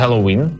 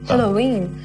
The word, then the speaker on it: Хеллоуін: В суботу на свято Хеллоуін, я правильно сказала? – Хеллоуін. – Хеллоуін.